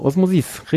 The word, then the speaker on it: Osmosis: Wie spricht man das in Französisch? Osmosis? – Genau, Osmosis, richtig. – Quatschig betonen letzte. Und dann irgendwie die Vokale verstümmeln. Und schon hast du Französisch. – Genau, auf die letzte Dings.